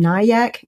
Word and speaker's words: Nyack: It'll air later, but his bookstore opens tomorrow, and that is Big Red Books at 120 Main Street in Nyack, New York.